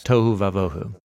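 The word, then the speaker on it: Tohu vavohu (תֹהוּ וָבֹהוּ): And then in Hebrew, wild and waste has a cool... – It rhymes. – Rhymes. – Yes. Tohu vavohu.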